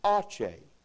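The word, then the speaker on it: Aceh: A-C-E-H. I didn't know how to pronounce it. How do you pronounce this? So I got there and I learned it's pronounced Ache. Ache. Try it with me. Ready? Ache. Good job.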